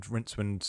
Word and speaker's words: Rincewind: The star of the first couple of books he did, the main character, was a wizard called Rincewind, and that's who you play in Rincewind. – Rincewind, yes.